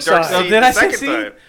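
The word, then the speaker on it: Darkseid: Well, I've always called him Dark Seed at first, and then I started calling him Dark Side once I realized that's how everybody says that you're supposed to pronounce Seed.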